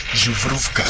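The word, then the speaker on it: Zubrówka: El Zubrovka. – Zubrovka. Sí, me explicó una vez Sergio Pitón que tenía que pronunciar Zubrovka. – Ah, pues está. Sí, me lo has dicho muy bien.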